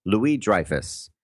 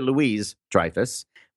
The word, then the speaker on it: Louis-Dreyfus: One celebrity that wasn't there, I don't think, is Julia Louis-Dreyfus. And I mention her because I always mispronounce her name. I always say Julia Louis-Dreyfus when it should be Julia King of France, Louis-Dreyfus. I always, add the S.